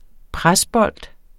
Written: presbold

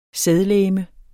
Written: sædlegeme

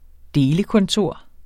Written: delekontor

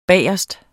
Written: BAGERSTE

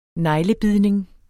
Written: neglebidning

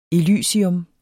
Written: elysium

